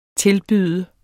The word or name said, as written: tilbyde